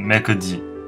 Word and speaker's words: mercredi: 第二点就是星期三 mercredi 这个音一开始可能比较难发，一开始可能比较难发，但是一定要把每一个细节都发出来，不要因为速度而牺牲了发音的准确性，不要发成 mercredi。